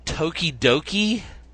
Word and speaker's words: Tokidoki: Marvel has been releasing Tokidoki, is that how you say it? – Yeah. – Tokidoki blind boxes with mini anime versions of the characters.